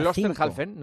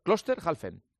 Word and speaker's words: Klosterhalfen: Klosterhalfen. – Constanz Klosterhalfen. – Que hizo 1:05. – Klosterhalfen, no es tan difícil. Hay que dividirlo en dos. Klosterhalfen, ya está.